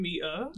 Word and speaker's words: Miah: How did I say that? And Miah. Yeah. There you go, that's it.